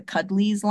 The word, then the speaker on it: Cuddlieez: Because I have one of the, this is the Cuddlies line of plush. It's spelled Cuddlieez.